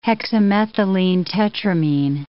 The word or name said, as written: hexamethylenetetramine